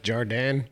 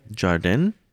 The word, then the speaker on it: Jardin: I think it's pronounced Jardin. – Jardin? – Yeah, the way she says it. She has a heavy French accent, so when she says it.